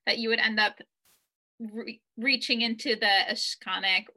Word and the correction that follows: Akashic: records. I hope I said that right. – Akashic, Akashic, Akashic.